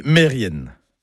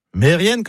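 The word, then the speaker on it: merien: Mais si je dis Mérien, bah oui, vous allez reconnaître le nom de famille, Kermérien, le nom de lieu. Eh ben non, tout ça, c'est faux. C'est Mérienne, Kermérienne.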